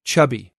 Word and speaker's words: Csabi: And C-S-A-B-I pronounced chubby. – C-S-A-B-I.